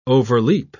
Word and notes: overleap: British English

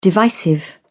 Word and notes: divisive: British English

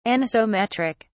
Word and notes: anisometric: British English

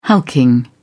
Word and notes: hulking: British English

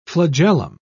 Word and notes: flagellum: British English

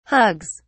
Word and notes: hugs: British English

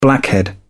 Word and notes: blackhead: British English

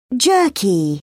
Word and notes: jerky: British English

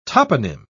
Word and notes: toponym: British English

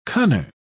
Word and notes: cunner: British English

